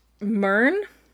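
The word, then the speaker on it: Mhurn: And Mern, I think this is how you say it. It's M-H-U-R-N.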